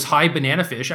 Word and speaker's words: High Bananafish: Dude, you could have just told me her screen name was High Bananafish. I didn't realize that. I just like skipped out of a syllable. I've read it as Hibanafish for like, I don't know, a year or whatever.